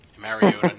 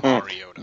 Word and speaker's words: Mariota: Mariota, not Mariota.